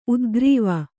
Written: ಉದ್ಗ್ರೀವ

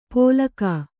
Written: ಪೋಲಕಾ

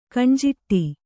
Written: ಕಣ್ಜಾಡೆ

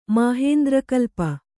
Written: ಮಾಹೇಂದ್ರಕಲ್ಪ

